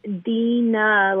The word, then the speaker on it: Deana: Um, so yes, it's Deana, like like two e's, but um, it's spelled like Dean with an A on the end.